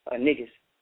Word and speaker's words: Negus: You know, it's spelled N-E-G-U-S, niggas. you know, and that word actually means king, African king, Ethiopian king.